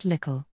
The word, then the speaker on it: little: The use of ickle, in South Yorkshire and particularly in the Rotherham area, hospital is pronounced hospickle and little is pronounced lickle.